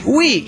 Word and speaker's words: Wii: Paz y amor y una carga de sueños. Bueno, queremos que lo pueda cumplir. – Sí, postscriptum. El Wii se pronuncia así, Wii, y no Y.